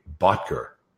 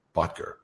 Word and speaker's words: Bottger: The one, the only, Kimberly Bottger. Botker. Botker. Botker. Botker. Botker. – Botker. Good yes? morning. You said it right the first time.